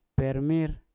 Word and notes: per mir: mpur

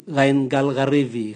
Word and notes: gaingal garivi: Raga

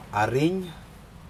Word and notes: ariN: Aneityum

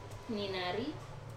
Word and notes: ninari: Wamesa

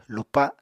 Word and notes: lupa7: Apatani

